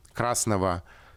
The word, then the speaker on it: красного: Ну вот смотрите, например, в окончаниях прилагательных. Красного, синего, «а, какого. Мы произносим «в», а пишем букву «г» вот эта уникальная ситуация, когда мы, э, ладно, там на конце слов, например, пишем букву «г», хотя произносим звук «к».